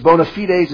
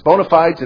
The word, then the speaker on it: bona fides: It says in contemporary English, bona fides is sometimes, by the way, it's pronounced both ways. It's bona fides and bona fides, depending on whether you're American or English, so you can choose either one.